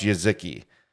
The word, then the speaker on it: Jezyki: J-E-Z-Y-K-I. – Y-K-I. Pronounced Jezki.